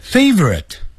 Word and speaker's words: favorite: favorite 本来按照标准发音是三个音节 favorite，可是老外音念快变成两个音节 favorite， favorite， favorite， favorite， favorite，我最喜欢的歌曲啊，最喜欢的歌曲是什么呢？